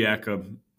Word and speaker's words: Jacob: Would it be like Jacob? Because it's... – It would be Jacob.